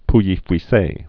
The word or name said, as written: pouilly-fuisses